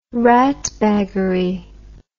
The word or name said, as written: ratbaggery